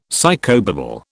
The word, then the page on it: psychobabble: British English